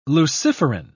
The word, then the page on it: luciferin: British English